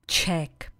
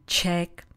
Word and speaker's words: check: Kdyby po vás někdo chtěl vědět, jaké jste národnosti, nebo kdybyste to měli vyplnit v dotazníku, napsali byste nebo řekli byste Czech, český, česká, Čech, češka. I'm Czech. Jsem Čech, jsem Češka. A slovíčko, které se vyslovuje úplně stejně, Czech, jenom se jinak píše, znamená prověřit, ověřit, zkontrolovat.